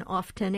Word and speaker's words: often: But there's nothing wrong with pronouncing the T in often. It is an acceptable pronunciation, but it's by no means more correct than without.